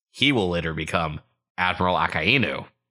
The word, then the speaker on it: Akainu: Also, it's pronounced Admiral Akainu. – Akainu, whatever. I'm just reading the portmanteau. Yeah. – No, I got what you said. Akainu, because Inu's dog.